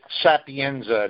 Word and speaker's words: Sapienza: Okay, it's Richard Sapienza. That's S A P I E N Z A. – Sapienza. Am I saying it right?